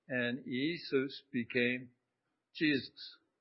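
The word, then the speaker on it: Iēsous: The Greek actually given in this passage is pronounced something like Eisus.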